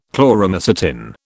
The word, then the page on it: Chloromycetin: British English